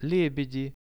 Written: lebedi

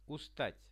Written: ustat

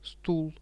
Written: stul

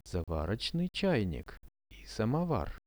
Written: zavar chainik i samovar

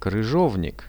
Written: kryzhovnik

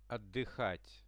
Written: otdyhat

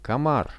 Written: komar

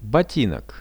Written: botinok

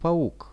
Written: pauk

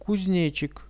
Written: kuznechik

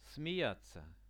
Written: smeiatsia

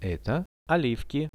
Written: eto olivki

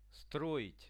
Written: stroit

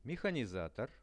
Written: mehanizator

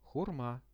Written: hurma

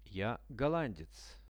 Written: ja gollandec

